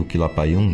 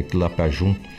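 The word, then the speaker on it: Quilapayún: Uh, outros pronunciam Quilapayum, mas é Quilapajum,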